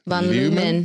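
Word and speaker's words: Van Leeuwen: Uh, Van I still don't know how to say it. – Van Lumen.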